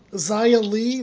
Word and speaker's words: Xia Li: Also, Xia Li, that's how you say her name.